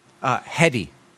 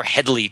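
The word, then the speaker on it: Headey: Like, and Lena and Lita, tell me how to pronounce her last name again. – Hedy. – I call her Hedley too much. It's Lena Hedy, of Game of Thrones fame, and Terminator, Sarah Connor Chronicles.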